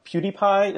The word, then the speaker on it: PewDiePie: So that bizarre guy, um, PewDiePie, is that how you say his name? – Yeah.